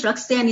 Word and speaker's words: Rox-Anne: So Facebook, it is ROX dash, capital letter A, N-N-E. You notice Rox-Anne is spelled very uniquely. So it's ROX dash, capital letter A, N-N-E.